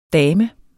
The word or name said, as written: dame